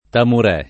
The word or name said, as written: tamurè